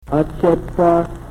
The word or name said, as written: o chepor